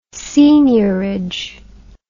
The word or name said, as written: seigneurage